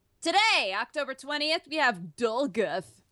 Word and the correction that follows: Dolguth: Dolguth. – How do you spell that? – D-O-L-G-U-T-H.